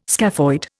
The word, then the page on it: scaphoid: Indian